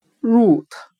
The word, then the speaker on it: root: 我们可以用谐音法来记忆这个单词 root 根，它的发音很像汉语的入土。我们这样来联想这个单词的意思：植物的根部。深深的扎入到泥土中。今天所学的单词 root，r o o t，表示根，我们就可以通过它的发音联想到汉语的入土，植物的根深入到泥土中，root，根。